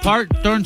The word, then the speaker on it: Parton: Oye, pues la dirección, Eddie, es 1660 North Parter Street. Parter Street, 1660 North Parton Street. – ¿Cómo se escribe la calle? – Es P-A-R-T-O-N.